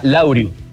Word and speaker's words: Laureus: ¿Cómo se pronuncia Laureus? Laureus. – Se pronuncia Laureus.